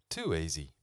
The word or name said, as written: too easy